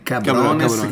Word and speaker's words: Cabrones: Pronuncia bene Cabrones, che non sembra Lebrones. – No, Cabrones.